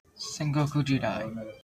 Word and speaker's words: Sengoku Jidai: Sengoku Jidai.